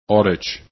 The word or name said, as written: outage